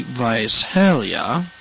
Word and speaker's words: Visalia: The biggest population near us would probably be Visalia. You got to say it like that. And you got to stick your pinky out while you're doing it.